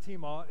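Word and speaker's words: timē: But honor, on the flip side, is the Greek word tima, or it looks like time, but it's pronounced tima.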